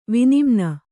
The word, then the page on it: ವಿನಿಮ್ನ: vinimna